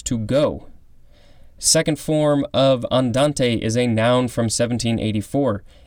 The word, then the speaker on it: andante: Orthorhombic is spelled O-R-T-H-O-R-H-O-M-B-I-C. Next we have andante. We have two forms, and they both will be the last for this episode. A-N-D-A-N-T-E. It could also be pronounced andante.